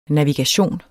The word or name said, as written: navigation